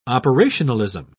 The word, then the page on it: operationalism: British English